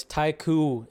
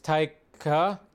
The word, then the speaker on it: Taika: So it's Taiku. It's Taika. Taika. Waititi.